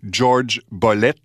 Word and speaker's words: Jorge Bolet: que je vais prononcer comme il le faisait lui-même, George Bolet, au Carnegie Hall de New York en février 1974.